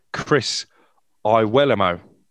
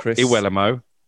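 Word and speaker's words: Iwelumo: Well, it was Chris Iwelamo, but I have to pull you up there because he's told me this evening the correct pronunciation of his surname is actually Chris Iwelamo, not Chris Iwelamo, Iwelamo.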